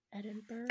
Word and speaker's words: Edinburgh: So the World's End Murders is the name given for a case where I think it's in, is it Edinburgh or Edinburgh? How do you pronounce that? I didn't look it up. – How do I say it? I was thinking Edinburgh. – Edinburgh. Yeah. Edinburgh.